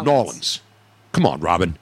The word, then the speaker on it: New Orleans: They say New Orleans. – Yeah, that's how they say it. – It's like N-A-W-L-I-N-S. New Orleans.